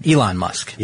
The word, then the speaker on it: Elon: Elon Musk, or am I saying that right, Ellen? Elon Musk.